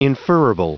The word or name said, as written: inferrible